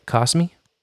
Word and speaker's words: Kosmi: Eastern on Cosmi. I think I'm saying that right. Cosmi? – K-O-S-M-I. – That's right.